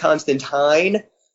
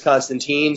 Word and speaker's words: Constantine: The nerd in me wants, like, anytime I say John Constantine, like, the uber nerd in me makes me go, well, it's pronounced Constantine, but after the TV series and everything else, I understand that as far as most people are concerned, it's Constantine, so I can either be that douche, or I can just pronounce it Constantine.